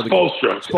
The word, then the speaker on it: Spoelstra: Spinola, how do you say his? I don't even know. – Spoelstra. Eric Spoelstra.